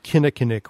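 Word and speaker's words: Kinnickinnic: as I'll pronounce it the Milwaukee way, the Kinnickinnick River.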